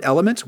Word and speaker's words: LMNT: Element, which is spelled L-M-N-T.